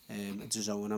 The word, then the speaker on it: DAZN: So I've signed up to DAZN, or DAZN. DAZN, I'm told reliably that is how you pronounce it.